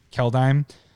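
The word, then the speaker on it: Kaldheim: So the new, what is it, Keldeum? How do you say it? – Keldeim? – Keldeim.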